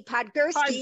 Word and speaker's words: Podgurski: You got to help me, Podgurski.